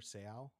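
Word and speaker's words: Seau: It's Junior Sehow, like that. – Sehow. – No, it's Sehow. Junior Sehow. – Sehow. – Sehow. – No, he's not Japanese. This isn't like a... Sehow.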